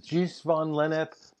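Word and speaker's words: Gijs van Lennep: I mean, I pronounced it Gis van Lennep, but that was a great conversation.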